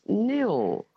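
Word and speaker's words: Nhill: The H is silent, and it's N-H-I-L-L, of course, and it's pronounced nil.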